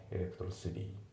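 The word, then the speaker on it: electricity: で、あと発音なんだけど、まあ、またエレクトロシティのところのね T が濁りますね。エレクトロシティだね。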